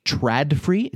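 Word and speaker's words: TRÅDFRI: the TradFree. It's spelled TRADFRI gateway, and that's on sale for $29.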